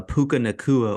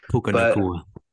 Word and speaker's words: Puka Nacua: Rams win 29-23 off a Puka Nacua overtime touchdown. – Great work on the pronunciation there, too, by the way. He nailed that. – Puka Nacua.